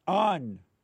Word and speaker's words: Ahn: I had a very interesting discussion with Dr. Shay Hong. Hong. Whatever. A-H-N. How do you pronounce that? – On. – On. On.